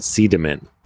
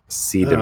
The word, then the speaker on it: Sideman: But there's a little town in Bali called Sidaman, and it's spelled Sideman, but it's pronounced Sidaman.